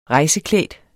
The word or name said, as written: rejseklædt